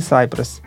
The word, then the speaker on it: Cypress: Кстати, не знаю, как правильно, Cypress или Cypress? Наверное, Cypress. На английском же ударение в первый слог.